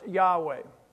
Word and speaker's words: Yahweh: It's in the Hebrew, it's the word, it's spelled YHWH. It's pronounced Yahweh is our God.